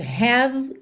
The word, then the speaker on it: have: Atenção aí a essas palavras que terminam em E, mas o E não é pronunciado. Você esquece dele completamente. Have termina no V. Have. Come termina no M.